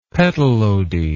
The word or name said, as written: petalody